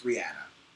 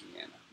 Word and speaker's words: Rihanna: It's not Rihanna, it's Rihanna. – It is Rihanna.